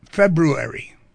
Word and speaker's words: February: How do you spell it? – With an R. – Yeah, F-E-B-R, right? February. – February. – February, right? It's February. – It's February. – It's like library.